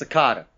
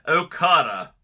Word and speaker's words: Okada: But that's Okada. Come on. – I'm sorry. How did I say it? – I don't know, but you didn't say fucking Okada. – Okada versus Ospreay, 5.25 stars.